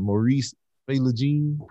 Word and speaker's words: Philogene: Mr. Maurice Philogene.